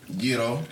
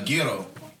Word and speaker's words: gyro: It's pronounced Y-E-E-R-O. – That's how you say it. – Gyro. – That's good. – I was saying gyro with the G, but apparently they don't have a G in that language.